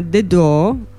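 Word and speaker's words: The Dø: Loro cantano in inglese, si sono conosciuti a Parigi nel 2007, sono Olivia e Dan, e il loro gruppo si chiama The Do, che prende la pronuncia appunto della nota musicale Do, no? Anche se si scrive The Do come in inglese, insomma D-O.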